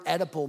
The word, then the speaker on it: Oedipal: In fact, I had to look it up, and initially I was even saying it wrong because the word is spelled O-E-D-I-P-A-L. So I was saying Oedipal. Come to find out the O is silent. And what she means by, hey, it will bring to an edible conclusion is Oedipus is a Greek god.